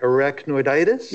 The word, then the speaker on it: arachnoiditis: So your client was diagnosed with, how do you pronounce it, arachnoiditis? – Yes.